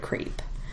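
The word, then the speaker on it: crêpe: And we had a little discussion in our family about the word crepe. Of course, we know it's French. And how would you pronounce it in French? – Crepe. – There we go.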